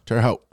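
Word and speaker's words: Terre Haute: I mean, I don't know much about Indiana State, but I know Terry Hout. Is that how you pronounce it? – Terry Hout.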